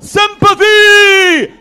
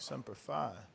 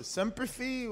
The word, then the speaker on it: Semper Fi: Is that how you say it, Semper Fee? – No. – I'm pretty sure they fucked that up. I thought it was. – Hell yeah. – I thought it was like Semper Fi.